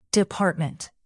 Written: department

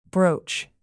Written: broach

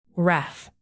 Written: wrath

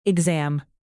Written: exam

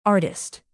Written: artist

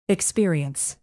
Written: experience

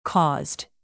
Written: caused

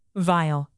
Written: vile

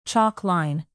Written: chalk line

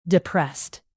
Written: depressed

